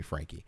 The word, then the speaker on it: Frankie: my very close family still calls me Frankie.